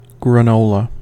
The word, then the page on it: granola: American English